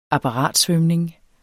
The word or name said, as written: apparatsvømning